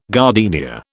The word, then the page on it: gardenia: British English